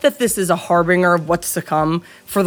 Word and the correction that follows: harbinger: It's the right word. You just pronounced it wrong. Like, I don't know what to do with that. Harbinger, yeah.